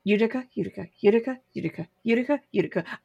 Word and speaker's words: Utica: Utica, the town is spelled with a Y. – It's a U. U-T-I-C-A. Utica.